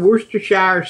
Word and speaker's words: Worcestershire: A teaspoon of Worcestershire sauce. Oh, I said that right, didn't I? – You did.